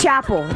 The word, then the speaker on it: Chappell: People are saying, like, Chappell? – Yeah. – No, it's Chappell. It's Chappell.